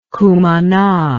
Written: cumana

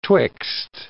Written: twixt

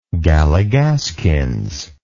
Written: gallygaskins

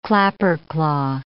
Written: clapperclaw

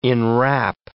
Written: enwrap

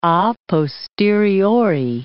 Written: a posteriori

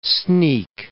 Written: sneak

